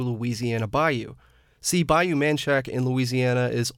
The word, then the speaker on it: Manchac: A third-generation Manchacan and president of the Manchaca Onion Creek Historical Association. She says Manchac is the correct pronunciation. – There is more support for the spelling M-A-N-C-H-A-C without the A on the end.